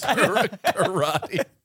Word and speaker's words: Corradi: That's kind of close. – Corradi. – Well, how would you pronounce it? – That actually is exactly it.